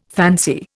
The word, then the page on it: fancy: Indian